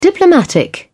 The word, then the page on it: diplomatic: British English